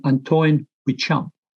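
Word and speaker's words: Antoine Béchamp: So the first one is I bumped into a French guy, I never know how to pronounce it, and Antoine Bechamp, B-E-C-H-A-M-P. Now, he was late 19th century, and his compatriot was the guy everyone knows, Louis Pasteur. So Louis Pasteur was very well spoken, quite rich. Antoine Bechamp was like the opposite to him from that perspective.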